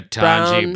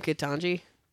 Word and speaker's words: Ketanji: Ketanji Brown. – See you in your dreams, Ketanji Brown Jackson. – Is her name Ketanji? – I mean, it's spelled K-E-T-A-N-J-I.